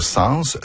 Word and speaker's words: sens: En français moderne, l'S final du mot « sens » se prononce toujours, sauf dans les expressions « sans dessus-dessous » et « sans devant-derrière ».